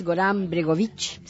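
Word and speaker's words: Goran Bregović: porque este autor es Goran Bregovic, según me han dicho, es así, se tiene que pronunciar así,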